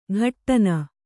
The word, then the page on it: ಘಟ್ಟನ: ghattana